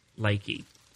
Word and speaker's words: Lakey: "Lakey," she said, pronouncing the name with a long E at the end.